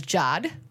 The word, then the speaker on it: Jade: I knew someone who it was spelled Jade, but they were known as Jod. – What? – Jade, J-A-D-E, but they pronounced it as Jod. – And it makes sense. – But they put an accent over the A, apparently.